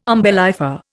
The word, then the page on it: umbellifer: Indian